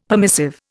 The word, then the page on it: permissive: Indian